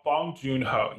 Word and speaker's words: Bong Joon Ho: John Ho. I'm probably mispronouncing that. – Boon. That's his. Bo- Bong Joon Ho.